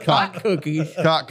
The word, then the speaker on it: Kahk: Cock cookies. – And I will tell you, they are fucking delicious. – Can you Google the pronunciation of that word? – I already did. It's exactly what I'm saying.